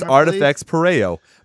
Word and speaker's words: Artifex Pareo: Why don't we start with Artifex Pareo with Passengers. – Pareo. – Did he say it right? – No, it's Artifex Pareo.